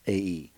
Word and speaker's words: Ai: And they returned to Joshua and said to him, Ai, by the way, is Ai, but it's pronounced Ai.